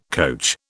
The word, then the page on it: coach: British English